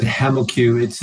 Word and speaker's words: HemoCue: Yeah, we pronounce it HemaQ. It's a mix between hemoglobin and the cuvette, which is key to the test that we provide.